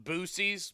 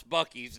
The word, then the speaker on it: Buc-ee's: I said, hey, I promise, because there's a bunch of idiots online that pronounce it Boosies because, well, they're stupid. Uh, and I said, look, on my show, I promise I will never mispronounce Buc-ee's.